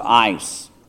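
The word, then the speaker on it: ice (εἰς): but it is the Greek word EIS, pronounced ice.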